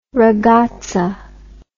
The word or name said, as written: ragazza